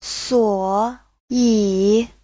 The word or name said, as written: suo3yi3